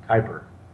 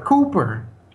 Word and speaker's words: Kuiper: Kuiper. – How do you spell it? – K-U-I-P-E-R. Kuiper. – Cooper.